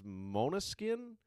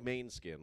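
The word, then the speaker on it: Måneskin: And they got one of those weird things over the A, and I think it's Mona skin, where it looks like main skin. Like, if you just said it in English, it's main skin. But I think technically it's pronounced Mona skin.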